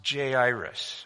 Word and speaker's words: Jairus: His name is pronounced Jairus, verse 41.